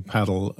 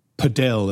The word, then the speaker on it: padel: You know, we, we mentioned in passing paddle and or or padel, as I think as uh, Charles pronounces it. Uh, paddle tennis, it's always going to be paddle to me, Charles, and pickleball.